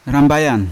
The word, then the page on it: rambayan: Yawa